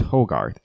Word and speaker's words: Hogarth: It's Hogarth. H-O-G-A-R-T-H. Hogarth. I heard Hogarth?